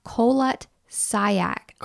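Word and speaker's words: Kholat Syakhl: Not colon cleanse. Kolot Sayek.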